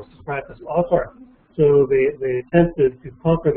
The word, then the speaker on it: autarky: was autarky. That's spelled A-U-T-A-R-K-Y, autarky, where,